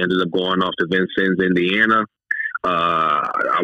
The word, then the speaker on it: Vincennes: Your dad follows the high school career, and I did not know how to pronounce this. Maybe you do. Vincensis, is that correct? Junior college? – Vincennes. – Okay. – Vincennes University in Indiana.